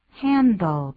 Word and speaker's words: handle: Handle 就是处理，这个 handle 我们把它念一下，注意到因为它有个 L 结尾，所以舌尖一定要抵上去哦。 – Handle, handle, 不要念成 handle, handle, handle, 所以你看舌尖抵上去的啊。